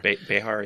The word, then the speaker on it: Behar: Behar. – Behar.